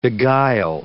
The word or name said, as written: beguile